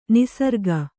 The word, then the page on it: ನಿಸರ್ಗ: niśarga